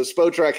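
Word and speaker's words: Spotrac: I never know how to pronounce it. – It's SpoTrack, apparently. – Okay. All right, so SpoTrack has Ojukwu is the most, because really the key number to look at is guaranteed money on these UDFA deals.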